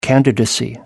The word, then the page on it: candidacy: American English